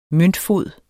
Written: møntfod

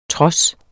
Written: trods

